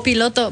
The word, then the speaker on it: piloto: Piloto. – But what do they need help with? – Is that what it is, piloto? – ¿Para qué, joven? – Wow, I said it right.